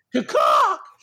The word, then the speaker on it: Cacok: Bro, their team right now is Kostas Antetokounmpo, Carmelo Anthony, Trevor Ariza, Kent Bazemore, Devontae Kakak, which is a weird last name. Kakak or Kakak is C-A-C-O-K. That's gonna be our dude there. Kakak! – That's how you got to say it from now on.